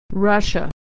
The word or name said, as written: Russia